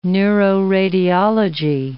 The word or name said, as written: neuroradiology